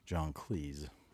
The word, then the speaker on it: John Cleese: Let me see if I can say that correctly, like cheese. John Cleese.